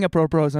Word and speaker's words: apropos: Did I say that right? – Yeah. Apropro. – Apropos. – Yes.